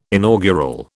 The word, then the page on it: inaugural: British English